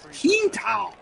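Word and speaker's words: Quintal: And, yeah, so Stefan Kintal, I love the way that Ken Wilson pronounced it. It was Kintal.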